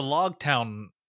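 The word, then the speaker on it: Loguetown: Because it's spelled L-O-G-U-E town. So, with my cursory knowledge of Japanese and their inability to say the L, I always thought it was Rogue Town. And I thought it was And I thought it was pronounced Rogue Town in the 4Kids dub, but I could be wrong. But they just keep pronouncing it Log Town, and I'm just like, why?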